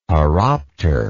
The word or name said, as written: horopter